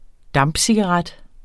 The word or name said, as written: dampcigaret